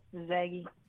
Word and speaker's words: Zaggy: Zaggy. – Oh, Zaggy. You're from Zaggy. Zaggy. – Spell it. – Z-A-G-G-Y.